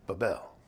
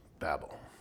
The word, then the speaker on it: Babel: And then it says in verse 10, the beginning of his kingdom was Babel or Babel. But it's often pronounced Babel.